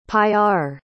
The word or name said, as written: paillard